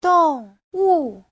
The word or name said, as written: dong4wu4